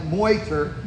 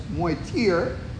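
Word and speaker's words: Motyer: It's spelled like Moiter, but it's Moitier. That's how I understand you to say it.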